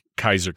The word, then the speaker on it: Caesar: By the way, Caesar, again, with the hard C, it's actually pronounced Kaiser.